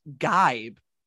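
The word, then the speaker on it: jibe: Okay, so it's actually pronounced Gibe, which is weird that it's spelled with J.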